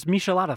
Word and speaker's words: Michelada: But it's Michelada Fest. – Michelada Fest, yeah. Yes. And that's coming up the first weekend of July. And if you don't know what a Michelada is, well, you need to just hang on because we've talked about it before, but we'll tell you all about Micheladas.